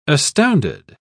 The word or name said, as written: astounded